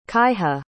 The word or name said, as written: kaiha